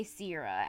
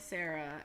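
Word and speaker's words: Sera: Because S-E-R-A, I'm not automatically pronouncing Sierra, but apparently you say Sierra and not Sarah. So I was saying Sarah, and I'm not going to remember to say Sierra unless there's two E's there.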